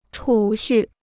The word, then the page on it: 储蓄: chǔ xù